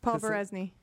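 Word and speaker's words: Poberezny: Yeah, speaking of Paul Probernesny, I can never say his name. Paul Probernesny. Paul. Proberesny. – Paul Berezny.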